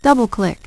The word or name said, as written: double-click